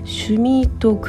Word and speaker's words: 趣味人: はい、まあ、こちらのスタジーもね、巨大マーケットですよね、はい、 DeNA は中高年向けの SNS シュミートクラブというのを運営してるんですけれども、これはあの趣味人って書いてシュミートって読むんですね、